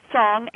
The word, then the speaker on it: Sogn: Song, S-O-G-N, from the Sognefjord in Norway.